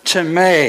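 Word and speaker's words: teme (τιμή): Well, that word honor, phonetically the word is spelled like time, T-I-M-E. It's a long E, so it's time, okay? The word teme.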